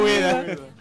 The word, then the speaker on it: Ueda: Uh, Uh, Ueda.